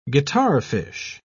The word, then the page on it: guitarfish: British English